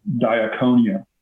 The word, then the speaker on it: diakonia: I'm not a guy who nerds out all the time in the Greek, but this Greek word for ministry is pronounced diakonia, which means, we think about the word ministry as something for the spiritually elite.